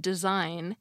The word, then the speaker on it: design: Although it can be tricky when we first learn to read and write that you don't pronounce the G in design, leaving it there is useful in order to see the relationship between its cousin word, designate.